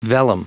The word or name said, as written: vellum